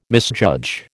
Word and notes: misjudge: American English

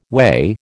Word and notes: whey: American English

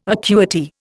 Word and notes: acuity: Indian

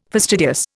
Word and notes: fastidious: Indian

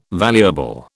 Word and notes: valuable: British English

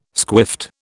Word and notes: squiffed: British English

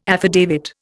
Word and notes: affidavit: Indian

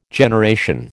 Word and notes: generation: American English

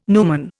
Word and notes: numen: Indian

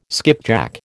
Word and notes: skipjack: American English

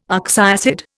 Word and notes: oxyacid: Indian

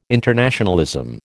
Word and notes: internationalism: American English

